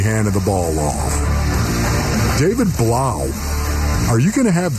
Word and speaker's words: Blough: Um, I'm going to say that my third and final Fulcrum football player is going to be David Blough rhymes with cow.